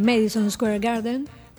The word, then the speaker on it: Madison Square Garden: Ya había dado un recital en el Madison Square Garden.